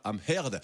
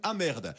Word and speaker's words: Amherd: Viola Amherd. Attention, prononcez bien le H de son nom de famille, sinon ça donne Amherd.